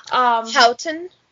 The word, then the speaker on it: Hatun: Houghton? – Yes. – H-A-T-U-N, so I think yes. That's how you say it.